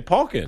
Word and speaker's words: Pauken: Today's Ask the Pros question of the day, brought to you by O'Reilly Auto Parts, comes from Jeremy Paukin? – Paukin? – I don't know. There's a question mark. – Spell it. – P-A-U-K-E-N? – P-A-U-K-E-N. Paukin.